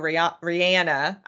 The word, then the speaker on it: Rihanna: And so, you know, halftime show, Rihanna, I think I've learned that that's how she pronounces her name.